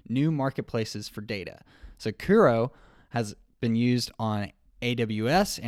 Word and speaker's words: Kuro: Am I saying that right, Brian? – As far as I know, yeah. Kuro, yeah.